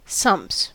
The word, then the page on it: sumps: American English